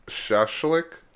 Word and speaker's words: shashlik: Well, I know I pronounced it like shellac, but it's actually like shashlik.